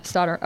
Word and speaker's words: Stothart: I think the H is silent. Stothart. – Oh, Stothart.